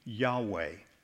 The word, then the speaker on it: Yahweh: You'll see on the screen, and would be pronounced, close pronunciation at least, would be Yahweh, YHWH, Yahweh, and sometimes spoken as Jehovah.